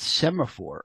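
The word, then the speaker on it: Semafor: Am I pronouncing that correctly? – Perfectly. – Okay, cool. Sometimes people are like, it looks one way, but it's like, no, it's not Semaphore, it's Semaphore.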